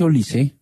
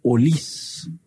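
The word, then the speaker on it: Olise: Es que no se dice Olis, que se dice Olis.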